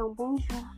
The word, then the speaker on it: bonjour: E esse R do bonjour, quando você fala, ele é escrito com R no final. Ele não é pronunciado com. É, como se fala? Com bastante é, prevalência na letra. Ele é quase mudo. É um bonjour. O R é bem mudinho.